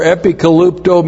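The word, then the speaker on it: epikalupto (ἐπικαλύπτω): E-P-I-K-A-L-U-P-T, long O. Epikalupto.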